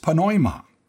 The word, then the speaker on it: pneuma: Then there's the word spirit, which in the original language of the New Testament is pneuma. This is another word I think you'll recognize in English, except in English we don't pronounce the P sound. Instead, for example, we say pneumonia. The P is silent.